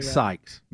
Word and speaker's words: Seitz: Helms gets very mad when I don't say it right, and you said it sights like I'm looking, like vision. – Yes, Greg right. Sykes.